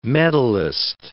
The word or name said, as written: medallist